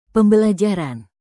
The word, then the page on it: pembelajaran: Indonesian